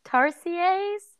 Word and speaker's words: tarsiers: Tarsiers? – Yeah.